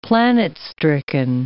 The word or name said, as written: planet-stricken